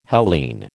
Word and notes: Hellene: American English

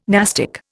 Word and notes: nastic: Indian

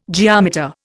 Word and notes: geometer: Indian

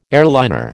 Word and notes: airliner: American English

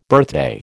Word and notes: birthday: American English

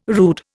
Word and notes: rude: Indian

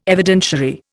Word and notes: Evidentiary: Indian

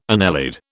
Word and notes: annelid: British English

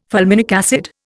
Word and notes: fulminic acid: Indian

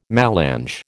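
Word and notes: mélange: American English

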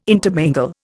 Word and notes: intermingle: Indian

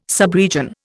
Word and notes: subregion: Indian

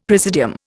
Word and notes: presidium: Indian

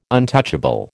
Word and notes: untouchable: American English

